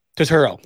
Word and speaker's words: Turturro: Turto. No, I'm saying that right. Turturro. There we go.